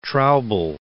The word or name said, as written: Traubel